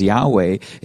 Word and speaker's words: Yahweh: Now this word, now from here, one of the reasons, kind of going back into it, of why these four letters, YHWH, we pronounce them as Yahweh,